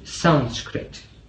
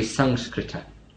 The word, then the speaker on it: Sanskrita: Now this word sanskrita or asanskrita, conditioned or unconditioned, is the same word, interestingly enough, as the name of the language Sanskrit. Sanskrit is the anglicized way of pronouncing it. It should be Sanskrita.